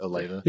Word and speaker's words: Olathe: Olathe. Is that how you pronounce it? Olathe? – Yes.